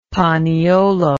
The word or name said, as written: paniolo